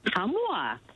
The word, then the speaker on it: Ottumwa: Ottumwa, Iowa.